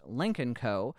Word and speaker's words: Lynk & Co: They've filed a trademark application for the brand's name, spelled L-Y-N-K, ampersand C-O, which is pronounced Lynk Co. Ford, understandably, is less than thrilled, given that they own a Lincoln, which Lynk Co. sounds an awful lot like.